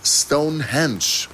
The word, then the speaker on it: Stonehenge: Stonehenge. Ja, man spricht es wirklich mit Betonung auf Henge, wohlgemerkt.